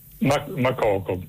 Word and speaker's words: Makoko: Makoko, sì, adesso non so se con l'accento finale alla francese è Makoko, però probabilmente siccome quello è un paese anglofono sarà in maniera diversa.